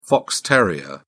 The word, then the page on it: fox terrier: British English